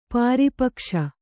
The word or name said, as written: ಪಾರಿಪಕ್ಷ